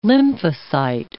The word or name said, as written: lymphocyte